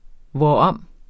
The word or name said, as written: hvorom